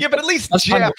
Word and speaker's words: Geoff: Jeff Calkins. It's spelled G-E-O-F-F.